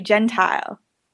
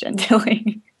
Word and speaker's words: Gentile: I just want to say people in America even pronounce John's name wrong. They say Gentile. – Oh, I was putting my first name. It's a very long story about my last name, so I won't get into it here. – I remember it is because of that Big Bopper song, like Chantilly Lace. That's how I remember your last name is pronounced Gentilly.